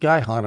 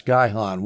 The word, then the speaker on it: Gihon: And I looked up how to pronounce Gihon. One YouTube video said it's Gihon. Another YouTube video said it's Gihon, which shows that there's nothing standard about pronunciation. I'm going to call it Gihon.